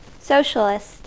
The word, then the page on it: socialist: American English